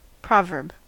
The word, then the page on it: proverb: American English